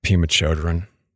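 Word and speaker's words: Pema Chödrön: but Pema Chodron.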